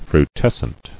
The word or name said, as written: Frutescent